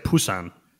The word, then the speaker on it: Busan: jo, men et eller andet sted skal det nok Altså, altså det er jo kun på koreansk, man siger Busan.